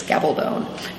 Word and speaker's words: Gabaldon: Hi, I'm Diana Gabaldon. I would be Gabaldon were we speaking Spanish, but if we're not, it's speaking English, it's Gabaldon, G-A-B-A-L-D-O-N, rhymes with stone.